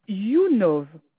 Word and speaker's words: YouNov: Point YouNov. YouNov, ça s'écrit Y-O-U. – Y-O-U.